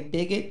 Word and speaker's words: Bigot: Some people might say Bigot. – That's what I thought it was. I said, oh, you got about 3 Y's. – Right. Because it's kind of, spelled like that, but he's actually from Haiti, and it's a French name, so it's Fred Bigot.